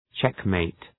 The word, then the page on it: checkmate: ‘tʃek,meıt